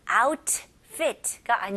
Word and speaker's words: outfit: 영어에는 주로 이제 T가, 특히 미국식 영어는 중간에 T가 있으면 T가 그렇게 강하게 발음을 안 해요. 그래서 outfit가 아니고요. T를 아주 가볍게 outfit.